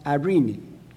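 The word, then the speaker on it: Irene (εἰρήνη): The word in the New Testament is a Greek word, and it's pronounced Irene.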